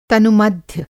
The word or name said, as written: तनुमध्य